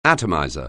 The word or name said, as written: atomizer